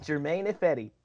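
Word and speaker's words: Germain Ifedi: Now, how do I pronounce it? – German Ifedi. – German Ifedi, perfect.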